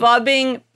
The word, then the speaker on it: phubbing: It's fubbing, P-H-U-B-B-I-N-G.